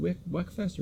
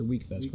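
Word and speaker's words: Wekfest: Is it Weckfest or Weekfest, Chris? – It's Weekfest for like 70th time, Grandpa. – But it's spelled Weck. – There's accents and shit over it.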